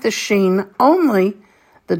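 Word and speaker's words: sheen (שין): It's not pronounced shin, but it is sheen.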